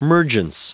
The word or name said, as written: mergence